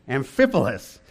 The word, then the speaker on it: Amphipolis: Here's how to pronounce it. Amphipolis, Apollonia, and 30 or so to Thessalonica.